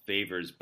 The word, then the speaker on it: Phavors: This is a band called Phavors, but Favors with it's spelled P H A V O R S instead with the traditional uh, spelling of it.